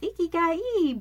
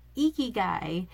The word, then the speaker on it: ikigai: And next podcast, we'll talk about the ikigai, which I pronounced wrong at my retreat. I was talking and telling people that it's ikigai, but it's ikigai.